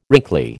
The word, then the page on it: wrinkly: American English